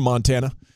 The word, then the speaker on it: Montana: I once met a guy from Montana. And he says, you got to stress the Mon if you want to say it like a Montanan.